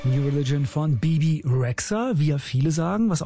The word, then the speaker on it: Rexha: aber eigentlich so richtig auf Albanisch wird sie Bebe Rexha ausgesprochen. Als wäre da noch ein D irgendwo, aber gut, wieder was dazugelernt.